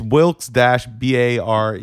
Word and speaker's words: Wilkes-Barre: It's Wilkes-Barre. – Yeah, everybody has a... I feel like I used to say Wilkes-Barre, and people are like, no, it's Wilkes-Barre.